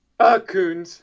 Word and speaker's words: Kouns: How do you pronounce this? Kouns? – Kouns. – Or Kouns. Okay. Well, you know English. You don't know until you hear the guy say it, right?